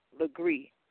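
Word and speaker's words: Legaré: and Soligree is S-O-L, Legree, L-E-G-A-R-E, because here in the Gullah Geechee Nation, the name Legree, usually of native Gullah Geechees, is spelled L-E-G-R-E-E. And if they're Anglo-Legrees, who are the people who descend from the Legrees that were enslaving Gullah Geechees, theirs is spelled L-E-G-A-R-E.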